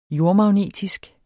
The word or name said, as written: jordmagnetisk